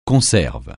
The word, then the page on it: conserve: francais